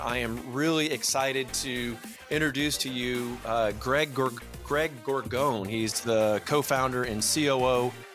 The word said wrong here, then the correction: Gorgone: And it's actually pronounced Gorgone. You pronounce the E at the end. – Gorgone.